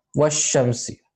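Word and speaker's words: washamsi (والشمس): That itself is a hint that this is not pronounced and this lam is not pronounced. It just joins straight to washamsi.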